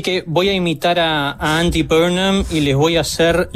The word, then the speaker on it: Andy Burnham: Yo voy a decir Andy Burnham y vos le vas a decir de otra manera, pero no importa.